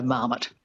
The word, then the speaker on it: marmot: It's had a lot of time, you know, to sort of be squished into an English model of stress, which would be the stress on the first syllable. So Marmot.